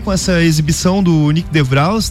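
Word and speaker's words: de Vries: a pronúncia dele pela origem do menino é DeVries. – DeVries.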